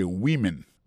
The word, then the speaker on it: women: Mais tandis que c'est le mot femme nan, au pluriel, qu'on n'a pas prononcé woman, encore c'est women. Many women.